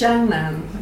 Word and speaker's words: Jiangnan: Oh, Jiangnan. – Shanghai, I can say, but I can't pronounce the region. So what's the region? How do you pronounce it? – Well, it's Jiangnan, which means literally south of the river.